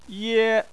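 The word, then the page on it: ye: tone 1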